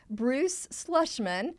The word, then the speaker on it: Bruce Schlesman: And to help us break it all down today, we have an awesome guest with us, Bruce Schlesman.